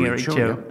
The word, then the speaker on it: Kericho: So Kericho, am I pronouncing Kericho? Kericho.